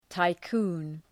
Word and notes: tycoon: taı’ku:n

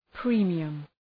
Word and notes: premium: ‘pri:mıəm

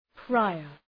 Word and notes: prior: ‘praıər

